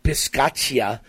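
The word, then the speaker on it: Bisaccia: First of all, the Raiders coach, I can never pronounce his last name, but my understanding is it's pronounced Rich Piscaccia.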